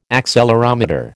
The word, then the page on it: accelerometer: American English